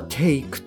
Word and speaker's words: take: で、ここであの、 live のですね、単語の語尾っていうのは E になるんですけれども、語尾の E というのはほとんど発音されないんですね。まあ、例えば take とか make、 cake。まあこれらなんかも同様で、後ろに E がくっついてるんですけれども、 E っていうのは発音しません。